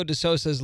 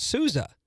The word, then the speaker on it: De Sousa: Like, it's almost like Silvio De Sousa's last name being pronounced De Sousa. It's like, but it's OU. That's Souza. Like, nope.